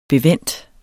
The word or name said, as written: bevendt